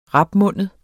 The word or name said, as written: RAPMUNDEDE